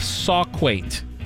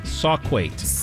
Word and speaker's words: Sauquoit: And Doug Feigl, and I looked this up, Adria, from New York, the town of Sauquait. Sauquait, New York. It's spelled S-A-U-Q-U-O-I-T. Sauquait.